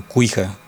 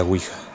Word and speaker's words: Ouija: Pero aquí en México, al menos en México, no sé si en toda América es O U hija con la O. Aunque algunos también en otras regiones aquí en México la pronuncian mal, y por supuesto del tablero este lo, lo llaman cuija.